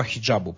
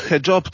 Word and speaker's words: hijab: E, tak, znaczy ściślej mówiąc, hijab to jest też hijab, mówiąc, e, mówię spolszczając wymowę, e, to jest, e, to jest generalnie zasłona.